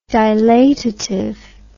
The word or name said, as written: dilatative